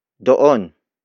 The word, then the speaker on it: doon: The next word is doon. Doon. Doon. Doon is spelled as D-O-O-N.